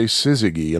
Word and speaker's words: Syzygy: but I sometimes say Syzygy, and I'll tell you why. If you hear me slip and pronounce it with a hard G, here's why.